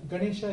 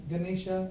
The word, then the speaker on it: Ganesha: Similarly, Ganesha. Ganesha is the right word, and Ganesha is generally like we tend to pronounce it.